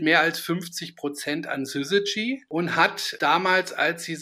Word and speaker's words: Syzygy: Also geschrieben wird das Unternehmen ja SYZYGY und gesprochen wird das Syzygy, das ist ein Online-Marketing-Spezialist.